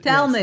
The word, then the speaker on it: Talmud: But Talmud, yes, Talmud, the Talmud.